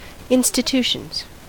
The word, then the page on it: institutions: American English